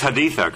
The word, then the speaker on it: Haditha: I'm looking very much forward to seeing Battle for Haditha. It's pronounced Haditha, correct? – Haditha, yeah.